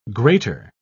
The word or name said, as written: grater